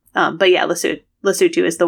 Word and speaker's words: Lesotho: and he responded, he said he pronounced it Lesotho, which, is a fine pronunciation if you've just read it. Lesotho, Alex corrects him, is the correct pronunciation.